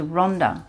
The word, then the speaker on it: Ronda: To Ronda, spelt without a H. Okay. – Yeah, Ronda.